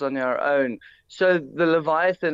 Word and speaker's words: Leviathan: Okay, talk to us about the Leviathan. Am I saying it correctly? – Yes, you are pronouncing that correctly.